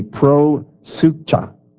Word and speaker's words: proseucha: There was no synagogue in Philippi, but there was a, and I'm going to try to pronounce this to you, I'll spell it to you first, P-R-O-S-E-U-C-H-A. There was a pro-sucha,